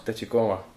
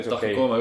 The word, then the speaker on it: Tachikoma: Tachikoma tahkooma ei ole, ma ei tea.